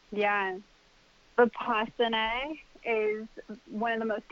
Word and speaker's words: Vipassana: Did I say that right? – Vipassana. – Vipassana, yeah, same thing.